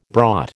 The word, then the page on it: brought: American English